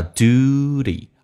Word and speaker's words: duty: 第一个 It is 有一个连音T 说话成 D 这个发音叫 It is It is the duty 美式发音当中这个单词趋向一发 U 这个发音 U 趋向一发 U 叫 duty 而且 T 要说话成 D Duty Duty of every citizen City T 说话成 D Citizen It is the duty